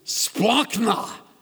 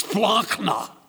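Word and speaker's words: splachna (σπλάγχνα): By the way, the word is splachna. It's one of my favorite words, splachna. You don't say it right unless something lands on the back of the neck of the person in front of you. Splachna. It's a guttural word. It's from the gut.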